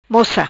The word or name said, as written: Moca